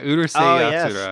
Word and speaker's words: Urusei Yatsura: Yeah, Urusei Yatsura.